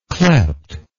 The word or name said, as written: clept